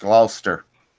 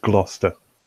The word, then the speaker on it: Gloucester: It looks like Gloucester, but it's pronounced Gloucester. Or am I getting that right, Richard? – Just Gloucester.